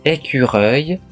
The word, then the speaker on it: écureuil: う、 u、い、 l という綴りで、発音は、うい。顎の力を抜いて口を大きめに開くのがポイントです。もう一度発音すると、 écureuil, é c ゆっくり読めばそれほど難しくないはずです。